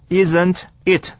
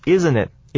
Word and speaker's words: Isn't it: Isn't it? 这边的发音呢有点复杂。 T 这个字母夹在 N 跟母音 I 的中间， T 是不发音的，所以我们念作 Isn't it? Isn't it?